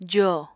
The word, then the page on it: jo: apatani